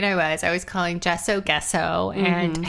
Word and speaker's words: gesso: So I took a collage class by Claudine Helmuth, who taught me that it's not gesso, it's gesso.